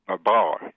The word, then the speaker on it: Babar: No, but in French, we always put the accent on the second, okay. – Babar, you know.